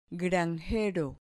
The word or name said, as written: granjero